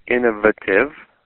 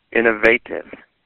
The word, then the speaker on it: innovative: Instead of a soft A, innovative, I suggest you try the hard A, innovative, next time you come across the word.